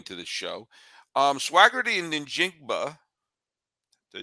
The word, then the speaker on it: Njigba: So you put an emphasis on the N, and then everything else is just pronounced the way it is. But Kanan Smith Njigba, yes.